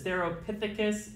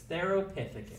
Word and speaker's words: Theropithecus: I took biological anthropology once, so I learned how to pronounce certain scientific names. This is Theropithecus gelada. – That's cool. Theropithecus? – Yes, Theropithecus.